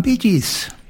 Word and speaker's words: Bee Gees: Og så av det at du skriver det slik som du uttaler det, så ble det Bee Gees.